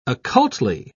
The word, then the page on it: occultly: British English